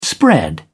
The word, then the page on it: spread out: British English